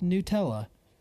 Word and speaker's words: Nutella: I hate that it's pronounced Nutella. You freaking make nuts. You use nuts. It's Nutella. – Bro. – It's nuts. Nutella. – Well, and you've seen the kid, right? He spells it out. N-U-T-E.